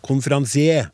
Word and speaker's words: konferansier: Et ord jeg uttaler konferansier. I tråd med det som er oppgitt som eneste uttale i både Naob, bokmålsordboka og nynorskordboka men jeg må innrømme at jeg kjenner meg nokså alene om å uttale det på denne måten, og jeg får en sterk følelse av at jeg språklig nærmer meg 107 år.